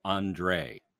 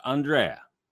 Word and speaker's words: Andrae: Emil Andrae, not Andrea. You got to get the name right.